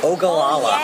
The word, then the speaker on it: Ogallala: When you said gingham before, and also Ogallala. – Ogallala. – Yes. – Ogallala, Nebraska.